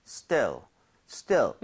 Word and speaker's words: still: 얘는 입모양을 옆으로 이렇게 벌리는 것이 아니라 입모양을 그대로 두고 그냥 still, still. 그러니까 얼핏 잘못 들으면 약간 스텔인가 싶을 정도로 아. 입을 벌리지 마세요.